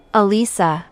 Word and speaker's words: Alisa: Alisa. – Alisa? How do you spell that? – A-L-I-S-A.